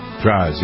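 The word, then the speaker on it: Drozd: Steven, is it Draws? Is that how you pronounce his name from the Flaming Lips? – I believe so, yeah. – Draws, yeah.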